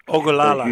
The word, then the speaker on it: Ogallala: Ogallala.